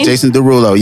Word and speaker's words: Jason Derulo: Is that how you pronounce his name? – Jason Derulo, yes.